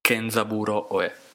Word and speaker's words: Kenzaburo Oe: In pratica, cioè noi abbiamo iniziato questa cosa, abbiamo tutti iniziato a leggere questo libro di, oh, non so come si pronuncia, Ozambu- Oezamburo, Oezamburo. – Che, Kenzaburo Oe.